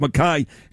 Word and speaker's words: McKay: How do you say her name? Mackay, right? It's spelt McKay, but it's Mackay.